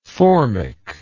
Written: formic

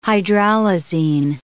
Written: hydralazine

